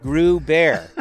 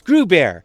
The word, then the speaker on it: Gruber: Because it's Gruber. Rhymes with Pooh Bear.